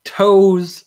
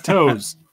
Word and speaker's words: Toews: Toews. – It's Toews. – Okay, that's how you spell it. – It's like just like Brett Favre.